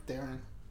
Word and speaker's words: Theron: How do you say her last name? Google's. – Theron. – Theron? – Theron. – Not Theron? – No. – So like, Theron? – Theron. – Like Darren, but with a T?